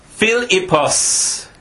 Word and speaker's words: Philippos: In Greek, the name is pronounced Philippos.